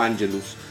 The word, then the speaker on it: Angelus: Se escribe Angelus y se pronuncia como Angelus.